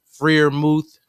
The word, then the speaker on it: Freiermuth: Pat Freiermuth, if I pronounced it correctly. – Yeah, Muth. – Freiermuth. – Freiermuth. Yes. – Pat. – Freiermuth.